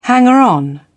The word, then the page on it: hanger-on: British English